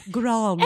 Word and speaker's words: Grande: Pronounce the E. – Grande.